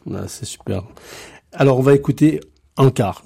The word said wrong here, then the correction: Yakar: je le dis bien. – Yakar. – Yakar, ça veut dire ? – Yakar, c'est une chanson d'espoir, hein, voilà.